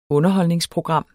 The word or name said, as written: Underholdningsprogram